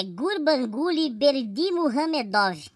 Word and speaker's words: Gurbanguly Berdimuhamedov: É Gurbanguly Berdimuhamedov.